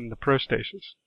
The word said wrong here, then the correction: protasis: Protasis, apodosis.